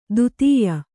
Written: ದತ್ತಯ್ಯ

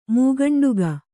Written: ಮೂಗಂಡುಗ

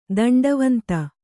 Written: ದಂಡವಂತ

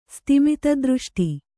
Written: ಸ್ತಿಮಿತದೃಷ್ಟಿ

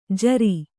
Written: ಝೂರಿ

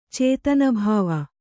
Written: ಚೇತನಭಾವ

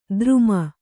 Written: ಧರುಮ

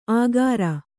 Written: ಆಗಾರ

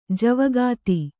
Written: ಜವಗಾತಿ

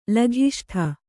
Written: ಲಘಿಷ್ಠ

